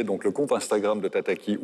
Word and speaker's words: Tataki: Euh, tataki. Voilà, trois syllabes, Tataki, c'est, on va dire, la chaîne jeune de la radio-télévision suisse.